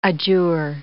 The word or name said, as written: adjure